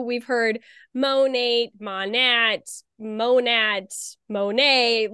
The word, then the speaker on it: Monat: But it is Monet,